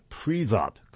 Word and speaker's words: Privat: It turns out that Kolomoiskoye's Privat companies, that's spelled P-R-I-V-A-T, were heavily invested in Georgia and had a very good relationship with Georgia and presumably Mr. Saakashvili as well.